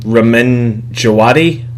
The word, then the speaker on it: Ramin Djawadi: I actually learned recently how to say his name, Ramin Djawadi.